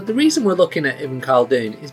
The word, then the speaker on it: Ibn Khaldun: I think Ibn Khaldun is right. Ibn Khaldun, yeah.